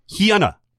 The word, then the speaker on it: Hyuna: Hyuna, by the way, is how you pronounce her name.